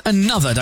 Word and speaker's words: ANOTR: Hour number two of the Weekend Show, with music on the way from Olivia Rodrigo, Calvin Harris and A-N-O-T-R. That's pronounced another, don't you know?